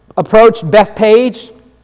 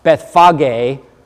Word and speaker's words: Bethphage: When he approached Bethpage, little Bible trivia here. It's actually pronounced Bethphage, but you could just say Bethpage because Bethphage is a mouthful.